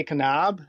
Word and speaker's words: Kanab: I don't know if it's our Michigan accent, but we say Kanab.